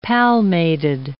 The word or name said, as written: palmated